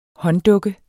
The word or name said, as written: hånddukke